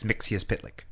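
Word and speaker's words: Mxyzptlk: Yeah, it's Mixius Pitlick.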